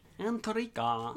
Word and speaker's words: Antarctica: I believe the T is silent. The second T. – The second. – Not the first T. – Antarctica.